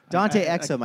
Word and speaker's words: Dante Exum: Dante Exum, I think, is how you say it. – Yeah, right, exactly, yeah.